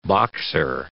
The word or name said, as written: boxer